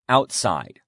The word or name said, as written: outside